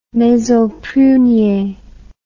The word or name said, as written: maison prunier